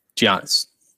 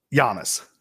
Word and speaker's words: Giannis: Giannis. – Giannis.